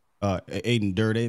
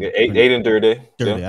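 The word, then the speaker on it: Aden Durde: You got a chance to speak with Coach AD, Aiden Durday. Am I saying that right? – Yeah. – I never can. – Aiden Durday. – I did it.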